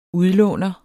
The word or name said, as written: udlåner